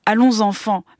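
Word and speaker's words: Allonsanfàn: D'abord, je vais le prononcer à la française parce que ça vient de la Marseillaise quand même. Allons enfants, mais qui s'écrit Allons Safan, des frères Taviani.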